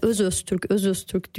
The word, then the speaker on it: Özöztürk: Ya bazıları cidden Öztürk diyor. Ben de diyorum yok Öztürk değil, Özöztürk.